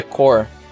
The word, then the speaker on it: Corps: O Corps em inglês se pronuncia core.